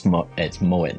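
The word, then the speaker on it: Mouat: My team just tell me it's Mowat now rather than Mowat, but who knows? – You're good with it.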